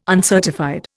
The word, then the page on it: uncertified: Indian